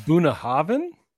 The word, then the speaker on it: Bunnahabhain: So, first of all, Bunnahabhain doesn't have a V in it. But in Gaelic stuff. – It's Bunnahabhain? – Yeah, yeah. In Gaelic, BH is pronounced V.